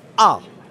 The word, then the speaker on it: Ah: Did I just say Ah? Yeah, that's how they spell his name, A-H. Now, I've never met a cat or a dog named Ah, but it's pronounced like a sarcastic scream.